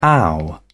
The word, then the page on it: ow: British English